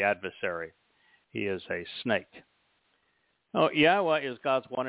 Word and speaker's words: Yahweh: Yahweh introduced his name to Moshe in the Torah, and he said that it was based upon the Hebrew verb haya, which means to exist. Haya has two of the, actually three letters comprising Yahweh's name, because the hays are repeated. And so haya tells you how to pronounce it, and it also tells you what it means.